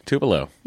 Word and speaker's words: Tupelo: Also, I pronounced Tupelo correctly last week. – Tupelo.